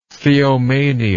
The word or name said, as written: theomania